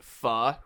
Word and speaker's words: pho: Not called Thai food near me, but it's called P-H-O. How do you pronounce that dish? – Pho. – That's what I thought. That's the way I've always said it. And this place is called P-H-O-K-I-M.